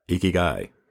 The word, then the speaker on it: ikigai: You mentioned a concept in your article there, see if I'm pronouncing it right, Ikigai? – Ikigai.